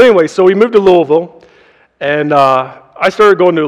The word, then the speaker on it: Louisville: And when you move to Kentucky, if you move to the Louisville area, they will quickly let you know that it is not pronounced Louisville, that it is Louisville, and you are definitely from out of state if you pronounce it any other way.